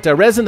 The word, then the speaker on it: Rezn: But Rezin, the band out of Illinois, they spell it R-E-Z-N.